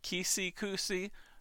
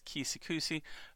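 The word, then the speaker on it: Keeseekoose: Kisikusi, I think that's how you say it. It's K-E-E-S-E-E-K-O-O-S-E. So I believe that's Kisikusi.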